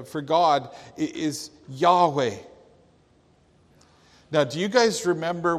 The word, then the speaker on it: Yahweh: And when you properly pronounce them, in fact, to correctly pronounce this name, you essentially breathe. As you inhale, and as you exhale, come on, let's do it together. His name is Yahweh.